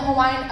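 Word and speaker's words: Hawaii (Hawaiʻi): But in the Hawaiian alphabet, W's are pronounced as V's. – So it's Hawaii. – So it's spelled H-A-W-A-I, Okina I. – Uh-huh. – So it's Hawaii. – Oh, okay.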